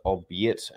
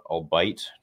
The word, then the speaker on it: albeit: Hey, Theory, just want to say albeit is pronounced albeit and not albite.